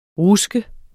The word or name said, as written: RUSKET